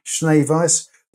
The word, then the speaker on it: Schneweis: It's spelled S-C-H-N-E-W-E-I-S, but it's pronounced Schneeweis.